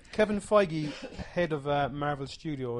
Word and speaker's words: Feige: F-E-I-G-E. Feige. – He's made fake, up as he goes along. – No, it's Feige. That's how he pronounced it.